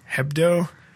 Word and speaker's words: Hebdo: How do you say the name of the magazine? Is it Charlie – is it Hebdo? – Hebdo.